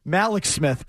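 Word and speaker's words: Mallex Smith: Malik Smith, by the way.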